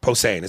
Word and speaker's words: Posehn: Posehn. – Posehn. Is that how you say that? – Yeah.